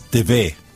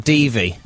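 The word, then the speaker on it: Devey: It's DeVay. – Oh, DeVay. – Hillary DeVay. – Not Devi. – No, not Devi.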